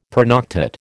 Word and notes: pernoctate: American English

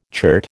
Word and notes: chert: American English

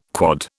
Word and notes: quod: British English

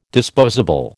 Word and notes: disposable: American English

